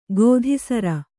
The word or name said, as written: ಗೋಧಿಸರ